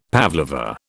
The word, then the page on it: pavlova: British English